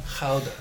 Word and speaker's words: Gouda: Gouda. That's how they say it. Gouda is a Dutch word. – Yeah. – Right? And I work with a Dutch person at my work, and she's like, that's how we say it.